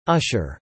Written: Usher

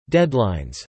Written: deadlines

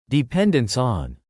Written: dependence on

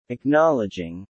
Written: acknowledging

